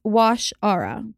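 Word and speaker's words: Waushara: It's Washara, by the way.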